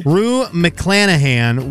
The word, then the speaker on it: Rue McClanahan: Room McClanahan, I'm saying that wrong, was in what sitcom that debuted on NBC in 1985? – I'm sorry, repeat that again? – Rue McClanahan was in what sitcom that debuted on NBC in 1985? – Cheers.